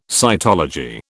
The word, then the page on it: cytology: British English